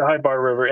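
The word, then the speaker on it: Chebar: how do you pronounce this? Sybar River or Chybar River? The Chybar River.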